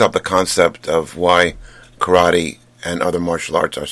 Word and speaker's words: karate: Properly pronounced, of course, it's karate. Kara means empty and te means hand.